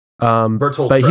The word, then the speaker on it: Bertolt Brecht: And the most famous of these were, there were 11 people, one of which testified, which was a playwright, Baltar Brecht. I'm probably mispronouncing his first name. – Bertolt Brecht. He was a German. – Bertolt Brecht, yeah.